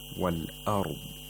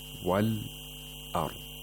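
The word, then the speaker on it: والأرض: So we have to pronounce this hamza. Wal ard. Because when we want to pronounce it, we have this qat'a. We have this disconnection of the voice. Wal ard. We have this glottal stop.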